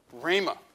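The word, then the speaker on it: Rhema (ῥῆμα): With a rough breathing mark, sort of like a silent H in front of it. Rhema.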